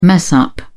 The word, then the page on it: mess up: British English